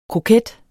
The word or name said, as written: kroket